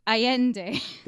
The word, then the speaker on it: Allende: Gunmen dressed all in black, their faces hidden, they began pouring into Allende. – Right. – Allende. – No, it's which Allende, is... like a Y. – Oh, Allende.